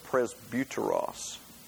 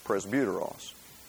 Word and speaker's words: presbyteros: But it is the word, I will pronounce it this way, presbyteros. It is the word from which we get presbytery in the Presbyterian church, presbyteros.